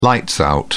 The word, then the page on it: lights out: British English